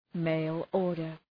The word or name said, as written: mail-order